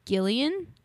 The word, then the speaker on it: Guillen: soldier Vanessa Guillen, I believe is how you pronounce her last name.